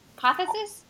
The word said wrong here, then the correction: apotheosis: Apotheosis. There you go. I always miss a syllable. – There's okay, an e so in there.